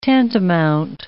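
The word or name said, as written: tantivy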